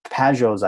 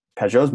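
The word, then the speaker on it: Pageau: And Pajot's ideas, I always pronounce his name weird, Pajot. – That's the way to pronounce it, isn't it? – Yeah, Pajot? I guess I put the emphasis on the wrong syllable, as they say.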